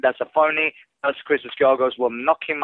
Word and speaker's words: Christos Giagos: Yeah, that was a very good pronunciation of Christos Giagos, even though most Americans would pronounce it Giagos.